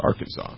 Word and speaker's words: Arkansas: Was Arkansas, and my teacher was like, nope, it's pronounced Arkansas.